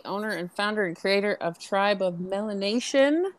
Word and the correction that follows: Melanin Nation: of Women, correct? I want to say that right. and you correct okay. me if I said it wrong. it's it's Melanin Nation.